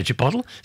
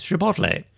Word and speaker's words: Chipotle: Chipotle is another one people mispronounce. Chipotle. I used to say Chipotle. No, there's an there. It's Chipotle.